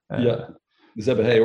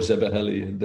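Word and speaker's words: Szebehely: Doing my research, and Zebehe, in fact, Zebehe, he told us to pronounce it Zebehe. Okay. I think he dumbed it down for the non-Hungarian native audience. Let me be polite, because I think a Hungarian later told me Zebeheli was the right word to pronounce.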